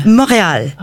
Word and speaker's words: Montreal: Montreal. – Oh, there yes. – Is it that's how you pronounce it? Yeah. – Yeah, Montreal. – Montreal. – Montreal in English.